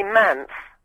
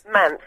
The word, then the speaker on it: Mance: And my family, I don't even know why I'm saying this live on air, my family call me Mance. – Mance? – Mance, which is the middle Mance. Syllable, because when I was a baby, my brother couldn't pronounce Samantha, so he called me Mance, and it kind of stuck.